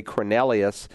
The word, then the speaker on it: Cornelius: And so if you remember in the 10th chapter, there was a man by the name of Cornelius, or maybe more appropriately Cornelius, as the Brits will pronounce his name.